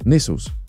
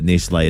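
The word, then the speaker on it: Nestlé: Nestles. – Make sure you ring your family, though, because if you just text them and say you'll never believe that Nestle is pronounced Nestle, then they won't be able to understand, will they? – I'll send them an audio message.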